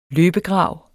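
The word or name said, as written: LØBEGRAV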